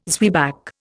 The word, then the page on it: zwieback: Indian